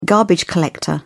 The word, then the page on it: garbage collector: British English